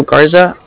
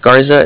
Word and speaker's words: Garza: My name's Alfonso Garza. It's spelled A-L-F-O-N-S-O. Mm-hmm. – My last name's Garza. It's G-A-R-Z-A.